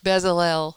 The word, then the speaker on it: Bezalel: And it's funny because later, after I met the Lord, I had several people tell me that I had the anointing of Bezalel. So God used how do you say that? – Bezalel.